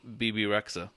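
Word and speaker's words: Bebe Rexha: BB Rexha. – Thank you. Because I was like, I don't know what to do with this X and H right now.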